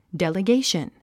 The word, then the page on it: delegation: dèliɡéiʃən; デリゲイション